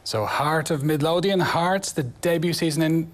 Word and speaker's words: Heart of Midlothian: Bueno, Rafa, pues este era el sonido del sorteo que se ha celebrado esta misma tarde, el Heart of Midlothian.